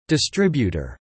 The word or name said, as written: distributor